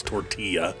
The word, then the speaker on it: tortilla: Time I hear that, I go, you know it's tortilla. You know how to say it.